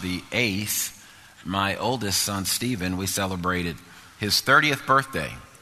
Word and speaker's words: Stephen: And I said, his name shall be Stephen, just like it's spelled, S-T-E-P-H-E-N.